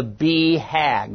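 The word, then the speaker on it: BHAG: As recently as yesterday, our certified performance team was talking about the power of the B-HAG, which is how it's pronounced.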